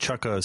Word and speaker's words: Chukka: I believe it's pronounced Chukka, C-H-U-K-K-A.